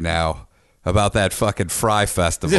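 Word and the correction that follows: Fyre: Is it Fry or Fire? – Or Fire. I don't know why I keep calling it Fry. Yes, it's the Fire Festival. It's spelled with a Y.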